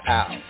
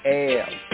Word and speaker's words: El: Some of the Hebrew Israelites pronounce it as Al, you know, and this is to the point of, like, illusion, right? And there could be a word, allusion, but here the proper pointing is El, because we study the more ancient Hebrew and Hebraic, we find Ayaleh.